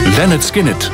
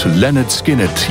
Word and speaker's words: Lynyrd Skynyrd: Lynyrd Skynyrd, zo spreek je dat uit. Lynyrd Skynyrd, jongens uit de afdeling Southern Rock.